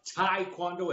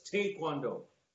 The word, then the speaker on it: Taekwondo: Okay, I'm gonna start with you're mispronouncing it. It's not Taekwondo, it's Taekwondo. Taekwondo.